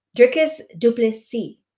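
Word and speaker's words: Dricus du Plessis: Dricus Duplessis.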